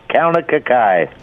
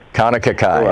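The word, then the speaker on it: Kaunakakai: Tom, again, Obi-Tom Kenobi, again, it is Kauna Kakai. – Thank you. Kauna Kakai, I got it.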